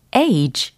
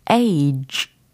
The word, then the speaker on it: age: 예상하신 것처럼 age, age, ag가 아니고 age 끝부분 소리 요렇게 입을 약간 이, 이 사운드를 내셔야 돼요. j, j, 입모양이 약간 이, 이러고 있어요. 상상을 해주시면 좋을 것 같습니다. 어쨌든 발음은 j, j, 이렇게 마무리해 보시면 되고, age, age, age.